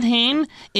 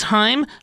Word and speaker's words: Haim: Actually, it's not Haim, it's Haim, like it rhymes with time.